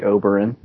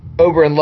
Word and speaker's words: Oberyn: Going back to the Red Viper, I would say his name is pronounced either Oberyn. I would say Oberyn. – Yeah, that's what I always went with. Oberyn loves him some whores.